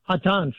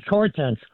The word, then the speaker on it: Hortense: In French, it's pronounced Hortense, but in English, it's Hortense.